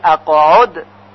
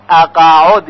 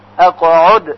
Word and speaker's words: اقعد: توجه داشته باشید که در کلمه اقعد قاف ساکن هست و بعضی ها شنیده شده که میگوین اقعد یعنی با فتحه، که این غلط هست، بلکه ساکن باید تلفظ بشه. اق اق اقعد.